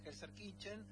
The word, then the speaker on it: Gelsenkirchen: Terminó 2-2 en un amistoso jugado en Gelsenkirchen, en la ciudad alemana.